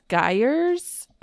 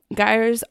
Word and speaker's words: gyres: But when people started tracking these, it taught the world a lot about, they're called gyres. I think that's how it's pronounced. It's G-Y-R-E-S.